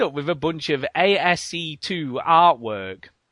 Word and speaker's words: ASCII: I'm assuming you might know this, Duke, what ASC2 is. – Yeah, that's the... when you're using little X's and zeros and ones and whatever to make an image. It's pronounced ASCII art.